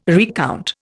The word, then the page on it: recount: Indian